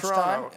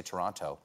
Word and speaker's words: Toronto: I know you're from Toronto because you don't say Toronto. – Say Toronto. – Yeah, Toronto. – The T is silent.